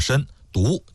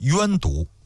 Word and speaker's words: 申犊: 申就是身体的申，犊就是毒品的毒。关于这个读音呢，有好几种说法，有些书上包括央视的纪录片上把它读成是冤毒，但是还有一个专门的文章啊，就说央视里边这个冤毒呢是读错了，就直接读作申犊就可以了。